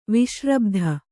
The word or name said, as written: ವಿಸ್ರಬ್ಧೆ